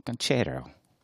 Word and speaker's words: Ganchero: G-A-N. – Uh-huh. – C-H-E-R-O. – C-H-E-R-O. G-A-N. – Yes. – C-H-E-R-O. Very good. Ganchero. Ganchero. – It's like ranchero, but with a G instead of an R. Ganchero.